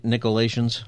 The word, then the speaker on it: Nicolaitans: Is that how you say it again, right? Nicolaitans? – Yeah, that's correct.